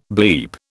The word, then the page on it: bleep: British English